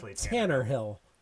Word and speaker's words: Tannehill: Nope. You said it right. Tannehill. It's clearly Tannehill.